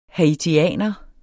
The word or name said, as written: haitianer